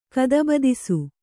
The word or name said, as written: ಗದಬದಿಸು